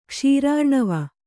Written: ಕ್ಷೀರಾರ್ಣವ

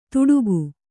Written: ತುಡುಕು

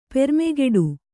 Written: ಪೆರ್ಮೆಗಿಡು

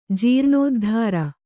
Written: ಜೀರ್ಣೋದ್ಧಾರ

